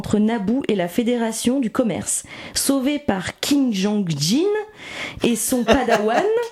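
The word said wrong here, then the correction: Qui-Gon Jinn: Pourquoi ça se prononce pas comme ça? – C'est Qui-Gon-jin. – Qui-Gon-jin.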